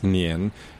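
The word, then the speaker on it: nian (年): The character for year is pronounced nian, and the character for sticky is also pronounced nian.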